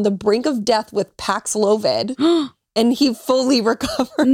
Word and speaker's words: Paxlovid: Oh, wait, that sounds right though, because it sounds like COVID. – That's what I think I say it that Paxlovid.